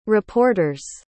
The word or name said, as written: reporters